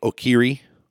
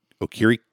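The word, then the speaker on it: Okereke: Quiddipay, Grover Stewart, DeForest Buckner, Yannick Ngakoue, Darius Leonard, Bobby Okiri. Sorry, I said that wrong. Okiri. Thank you.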